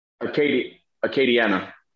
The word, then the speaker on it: Acadiana: How do you pronounce it? Acadiana. Acadiana.